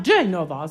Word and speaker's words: Genova: À La Spezia, l'annonce disait bien « Genova », ce qui est la façon italienne de désigner la ville de Gênes, avec l'accent sur la première syllabe « Genova ». J'ai toujours beaucoup insisté auprès de mes chanteurs pour qu'ils respectent les accents toniques. « Genova » et non « Genova », qui évidemment, en français, donnerait « Genève ».